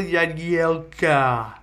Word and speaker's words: Jagielka: Phil Jagielka, which for the majority of the time I've known that guy, I've called him Jagielka, but apparently his name is pronounced with a y sound.